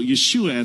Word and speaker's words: Yeshua: The Hebrew sh wasn't in their alphabet. They actually threw that letter out. Or the guttural sounds, they couldn't say any of them. So Yeshua at that time became Jesus for the Greeks, and then Jesus for the Romans.